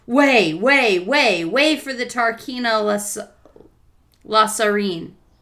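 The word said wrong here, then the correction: Lasaraleen: Lasaraline, or Lasaraline? – La- yes, there's another syllable in there.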